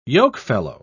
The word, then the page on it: yokefellow: English; British English